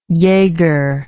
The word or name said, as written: jager